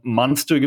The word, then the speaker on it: Munster: Ähm, hier gibt es so ein paar kleinere ähm, Munster, gibt es also Münster in englisch ausgesprochen, Mansfield.